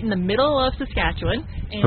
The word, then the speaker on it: Saskatchewan: Your family farms up in Saskatchewan. – Yes. – Did I say that correctly? – Saskatchewan.